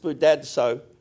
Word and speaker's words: spoudazo: Spoudazo is spelt spoudazo, but it's pronounced spoudadso, and it comes, doesn't it, from Hebrews 4, where that verse it says, run into rest or labor to enter into rest.